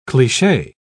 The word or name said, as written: cliche